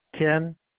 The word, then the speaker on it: Ken: Ken.